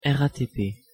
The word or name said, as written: RATP